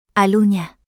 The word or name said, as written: Alunya